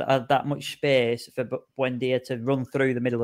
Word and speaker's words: Buendia: Buendia.